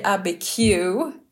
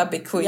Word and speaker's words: Abiquiu: Georgia O'Keefes hus i Abiquiu, inte Abiquiu, ja, som jag har försökt säga det på någon slags urinvånare amerikanska.